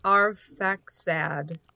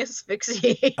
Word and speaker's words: Arphaxad: By the way, it's Arphaxad. – Arphaxad. – Yeah, but you were saying asphyxiate.